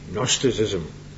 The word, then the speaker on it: Gnosticism: Gnosticism, spelt with a G, Gnosticism.